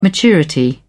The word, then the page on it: maturity: British English